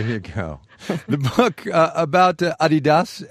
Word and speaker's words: Adidas: You've been saying Adidas, as in Adi Dassler, Adidas. Over here we say Adidas. Which one's right? – Oh, definitely the European pronunciation, and it's really strange. I really can't explain why you pronounce it differently in the US.